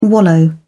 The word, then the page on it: wallow: British English